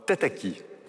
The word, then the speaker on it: Tataki: Euh, tataki. Voilà, trois syllabes, Tataki, c'est, on va dire, la chaîne jeune de la radio-télévision suisse.